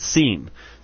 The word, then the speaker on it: Sean: His name is spelled S-E-A-N, and it's Seen.